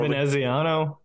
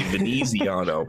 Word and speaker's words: Veneziano: You going to say it for Veneziano. – Me? – Veneziano. – Veneziano. I always pronounce the first E wrong. I want to say Veneziano for some reason, but Anthony Veneziano, and Alec Marsh for your two picks down in Northwest Arkansas.